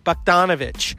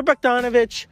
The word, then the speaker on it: Bogdanovich: Bogdanovich. Peter Bogdanovich.